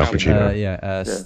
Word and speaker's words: Al Pacino: Yeah, Al Pacino.